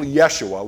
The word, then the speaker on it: Yeshua: The whole town was wondering how little Yeshua, which was his name, that's how they pronounced it in Aramaic, how little Yeshua the carpenter's son, the brother of James and Joseph and Judas.